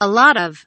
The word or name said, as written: a lot of